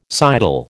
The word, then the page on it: sidle: American English